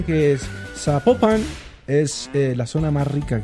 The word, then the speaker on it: Zapopan: Nos escriben los oyentes y que sapo, no se pronuncia Zapopan, sino que se pronuncia Zapopan. – Zapopan.